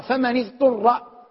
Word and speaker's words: اضطر: شرطية، والأصل أنها مبنية على السكون من، لكننا نقرأها فمن اضطر بالكسر لالتقاء الساكنين، لأن العرب ليس في كلامها توالي ساكنين، ليس في كلامها توالي ساكنين.